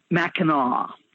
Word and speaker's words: Mackinac: Mackinac Island Suspension Bridge, is that how I pronounce it? Mackinac, yeah.